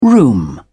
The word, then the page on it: room: British English